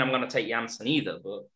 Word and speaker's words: Jansen: I'm calling him Jansen, by the way, and I'm doing the same for Danny Jansen. That J should not be pronounced, and I don't even care if he denies that himself.